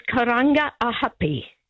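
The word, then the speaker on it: Karangahape: I had to ring up when you were talking about pronunciation because you kept referring to Karanga Happy Station for the station on the CRL. – Yes. – The actual correct name is Karanga Ahapi.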